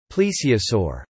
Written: plesiosaur

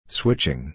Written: switching

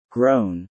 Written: grown

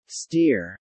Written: steer

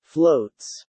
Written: floats

